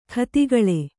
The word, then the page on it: ಖತಿಗಳೆ: khatigaḷe